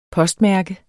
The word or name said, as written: postmærke